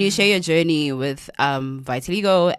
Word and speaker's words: vitiligo: Vitiligo. – Vitiligo. – No, there's so many. – Oh, yeah, it's the way I it's say vitiligo, easy. Some say vitiligo.